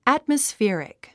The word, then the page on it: atmospheric: æ`tməsférik; アトマスフェリク